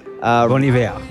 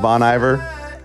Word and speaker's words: Bon Iver: How do you pronounce this? Bon Iver? – Mm-hmm. – I love this. – I'm pronouncing it douche. – I had to ask just to make sure. – Bon Iver. – Not Bon Iver.